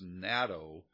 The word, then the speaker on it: natokinase: But it's this natokinase, and I'm going to pronounce it like that so you can kind of get the spelling. It's natokinase.